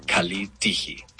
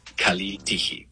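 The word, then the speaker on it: Kalitigi (Καλή τύχη): El griego moderno se dice Kalitigi. Kalitigi. Pronunciación aproximada.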